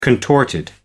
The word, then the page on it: contorted: British English